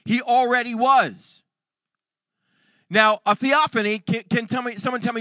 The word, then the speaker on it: theophany: Can everybody say theophany? Now, let's all spell it together. T-H-E-O-P-H-A-N-Y.